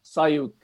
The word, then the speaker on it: Sioux: And he just looked puzzled, and then he went, Sioux. – That's a bit of a giveaway, mate, isn't it? It's not pronounced how it's spelt.